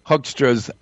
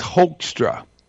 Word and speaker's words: Hoekstra: He mispronounces Hoekstra's name. He says Hoekstra, like hoax, H-O-A-X-tra. But it's pronounced Hoekstra.